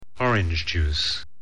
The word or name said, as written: orange juice